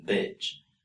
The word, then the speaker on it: bitch: The sounds we have here, according to the International Phonetic Alphabet, are E for beach and I for bitch. So as you can see and hear, there really is a difference when pronouncing beach and bitch.